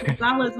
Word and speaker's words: Gonzalez: It's Gonzalez with two Z's.